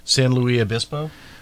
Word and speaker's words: San Luis Obispo: San Luis Obispo. – How do you say that again? – San Luis Obispo. – So you're doing kind of a French. As well as a Spanish.